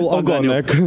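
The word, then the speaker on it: ogonek: Cum a apărut Ogonioc? Am pronunțat bine? Sau Ogonec. – Ogonec. – Ogonec. Ogon e coada și Ogonec este diminutiv de la acest cuvânt.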